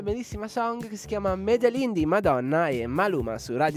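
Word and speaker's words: Medellín: Allora, il brano si intitola Med- Medellin. Med- si legge? – Come hai detto tu adesso, Bedelin. Bedelin. – Quella... Medelin, con la M.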